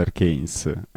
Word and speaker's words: Arcanes: Scusa, quindi si pronuncia Arcanes, eh? – The Arcanes, sì, perché ma solo perché fa più figo, in realtà. Volevo fare lo splendido pronunciandolo in latino, no? Ma in realtà è arcane.